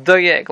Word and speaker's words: Doeg: Without the Aleph, it sounds more like Doeg, like Doug.